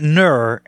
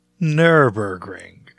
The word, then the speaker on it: Nürburgring: Yeah, it's not Nur and it's not new. It's Nürburgring. Yeah, I can't give you an easy way. You just need to know it's Nürburgring, and that's how you say it.